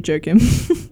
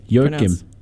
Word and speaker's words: Joakim: At you, Joakim. – Oh. – Yeah, That's actually not how you pronounce it. – Is it Joakim? – No. – How is it? – Joakim.